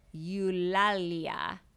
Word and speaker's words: Eulalia: Okay, E-U-L-A-L-I-A. Eulalia.